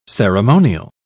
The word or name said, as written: ceremonial